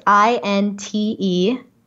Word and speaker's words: Integromat: Integromat. Okay. Yeah, it's INTEGROMAT.